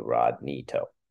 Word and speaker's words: Rodney To: Oh, see, social media, my Instagram is Rodney To, and it's how my name is spelled phonetically, RodkneeTow, Rodney To.